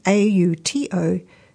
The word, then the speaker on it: autophagy: It's called, I'll spell it for you, AUTOPHAGY, pronounced autophagy.